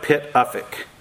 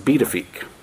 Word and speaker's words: Pituffik: Now that's spelled P-I-T-U-F-F-I-K. So it looks like Pit-Ufik, but it's pronounced Bitufik.